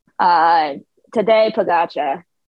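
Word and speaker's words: Tadej Pogačar: So how do you pronounce Primoz Roglic and Tadej Pogacar? Like, what's the correct way to say their names? – Primoz Roglic, obviously, and then Tadej Pogacar.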